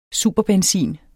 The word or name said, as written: superbenzin